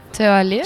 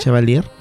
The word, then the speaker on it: Chevalier: Chevalier. – Chevalier.